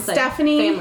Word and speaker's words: Stefanie: My name is Stephanie.